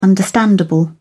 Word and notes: understandable: British English

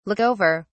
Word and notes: look over: British English

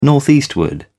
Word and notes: northeastward: British English

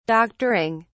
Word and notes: doctoring: British English

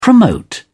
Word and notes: promote: British English

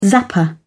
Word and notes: zapper: British English

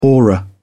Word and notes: aura: British English

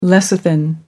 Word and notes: lecithin: American English

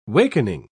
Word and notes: wakening: British English

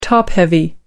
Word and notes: top-heavy: American English

